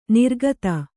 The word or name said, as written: ನೀರೋಗತೆ